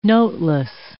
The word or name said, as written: noteless